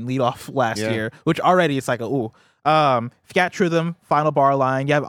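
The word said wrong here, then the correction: Theatrhythm: But yeah, Theater Rhythm.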